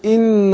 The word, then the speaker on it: إن: إن الله أبين الكسر نقول لما نبدأ بهمزة مكسورة بالهمزة المكسورة أنطق زي الإي بالإنجليزي مش مش إيه أقول إن الله ما أقولش إن لا إي مش إيه